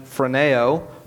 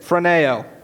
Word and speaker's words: phroneo: And there's a Greek word that is pronounced phroneo, or transliterated phroneo. Everybody say it, phroneo.